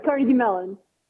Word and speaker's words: Carnegie Mellon: Is that pronounced Carnegie Mellon? – That's right, Carnegie Mellon.